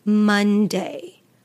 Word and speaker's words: Monday: And we say Monday, Monday. Now the O is actually pronounced more like a U, Monday, Monday.